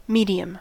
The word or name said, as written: medium